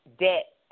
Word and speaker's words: debt: But we want you to leave the bad spending habits, bad saving habits, and bad debt habits behind. And yes, the B is silent.